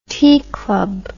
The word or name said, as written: tee club